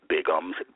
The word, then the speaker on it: Bigums: You can join me on my Instagram, which is my nickname, Bigums, B I G U M S, or at Twitter um, at Angelo Pop Music.